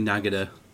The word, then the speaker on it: Nagada: Actually, with the flanging, it sounded like Nagada to me. – No, it's definitely Nagada.